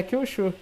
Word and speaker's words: Kyushu: Como é que se pronuncia? Kyushu? Kyushu, é, eu falaria Kyushu.